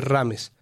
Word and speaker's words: James: Ay, entonces para explicarles cómo se, cómo se pronuncia James Rodríguez, uno escribe Rames.